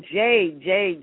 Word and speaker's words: Jayd: Her name is I hope I'm still saying it right, Jade? Is it J-A-Y-D? It's actually Jade.